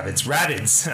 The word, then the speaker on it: Rabbids: Rapid, Rabbids. I keep pronouncing it wrong.